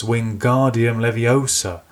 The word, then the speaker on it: Wingardium Leviosa: And Hermione, who he doesn't like anyway, corrects him and says, "You're saying it wrong, it's Wingardium Leviosa. Make the gar nice and long," and then she proceeds to show Ron how it's done.